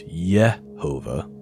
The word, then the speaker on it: Jehovah: By the way, the J sound, going back, the beginning of when English was being spoken wasn't pronounced as a J. J was pronounced as yeah, not juh. So just like in Hebrew, there is no juh sound. It's Yehovah.